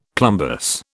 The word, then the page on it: plumbous: British English